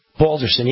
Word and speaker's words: Balderson: And of course, we've got the big victory for, Troy Balderson? I believe that's how you pronounce his name, in Ohio. Balderson, yeah.